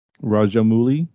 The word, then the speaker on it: Rajamouli: Rajamouli. Oh, cool. – I mean, I think I don't think the oo is pronounced as an oo. I think it's an ow.